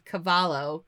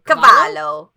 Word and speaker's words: Cavallo: Cavallo. – Cavallo. – Cavallo. – Cavallo. – I'm so used to pronouncing the double L in Spanish where you do it as a Y instead of an L.